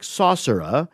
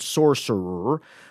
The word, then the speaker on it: sorcerer: The British style is to open up that last R, something like saucerer. The American style is to clamp down hard, sorcerer.